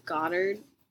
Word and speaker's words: Goddard: Yeah, But I think that because there's still some bad people that follow Scythe Goddard. Is that how you say it? – Yeah.